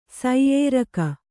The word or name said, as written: ಸೈಯೇರಕ